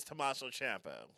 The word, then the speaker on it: Tommaso Ciampa: His name, the guy that he's thinking of is Tommaso Ciampa,